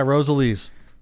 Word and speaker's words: Rosalie's: Yeah, Rosalie's.